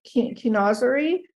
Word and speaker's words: chinoiserie: And I think you talk about Chinoisier, and say it correctly, Chinoisieri. – Yeah, Chinoiserie.